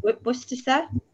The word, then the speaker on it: Worcestershire: Worcestershire. I'm still getting used to these names. Worcestershire? Is that right? I'm saying that right? – Worcestershire.